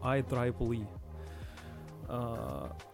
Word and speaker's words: IEEE: Оно произносится, я тоже для себя недавно узнал, I Triple Э, опубликовали а, статью с а, самыми высоко деменде, как это, востребованными а, языками программирования.